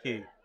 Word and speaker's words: Quay: I think it's Barnaby Key. Spelled like Quay. Say it like that.